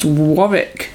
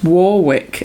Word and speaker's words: Warwick: I ended up looking him up on IMDb, and it said at some point in the trivia that his name is actually pronounced Warwick, not Warwick. And I was like, well... Of course it's pronounced Warwick. – Like the place.